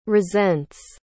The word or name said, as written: resents